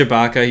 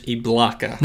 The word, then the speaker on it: Ibaka: Serge Ibaka, he's bounced around a little bit. – You mispronounced his name. – Oh, sorry. Serge Ibaka. – There it is.